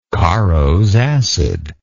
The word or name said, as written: Caro's acid